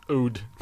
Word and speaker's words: oud: It's spelled O-U-D. – Ode.